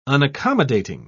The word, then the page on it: unaccommodating: British English